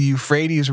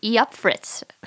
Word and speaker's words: Euphrates: Yeah, when I used to pronounce it the way it looks. So I used to say Yupfritz or something like that.